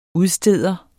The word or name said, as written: udsteder